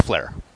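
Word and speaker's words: Fliehr: You pronounce it the same way his characters pronounce. It's Flair. – I believe it's Fleur. – It's spelled F-L-H-I-E-U-R, but it's pronounced the same as F-L-A-I-R.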